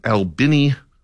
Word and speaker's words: Albany: This is a weird story out of Albany, Georgia. That is the correct pronunciation, in case you're wondering.